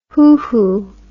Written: whowho

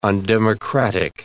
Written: undemocratic

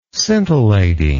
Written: scintillating

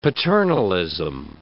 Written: paternalism

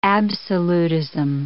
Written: absolutism

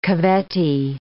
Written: cavetti

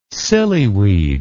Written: sillyweed

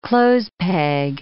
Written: clothes-peg

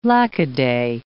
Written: lackaday

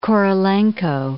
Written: Korolenko

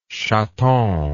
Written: chaton

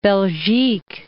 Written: Belgique

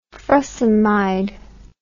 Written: frusemide

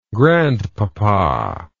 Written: grandpapa

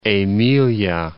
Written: Emilia

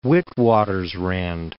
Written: Witwatersrand

